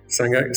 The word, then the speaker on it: Sangha: Lo he dicho bien, Sangha Activa Vilasa. – Exactamente. Sangha, sangha Activa, sí.